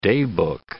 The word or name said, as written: daybook